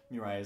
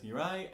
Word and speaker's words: Murail: Murail is spelled M-U-R-A-I-L.